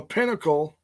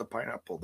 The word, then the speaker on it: Pinnacle: So the Pinnacle. Hey, I said it right this time. Not the pineapple. The Pinnacle.